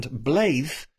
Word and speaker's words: Blaidd: A classic example, a lot of the words in it are Welsh, and Blaidd, who, by the way, is B-L-A-I-D-D, but double D is pronounced th, as in the.